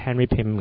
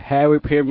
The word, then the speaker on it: Henry Pym: Henry 啊，其實 P 佢串就串 PYM，其實應該發音都係 I 字嘅，就係 Henry Pym 嘅。 – 係 Henry Pym 嘅，係啦，啱啱矯正咗我嘅發音啦。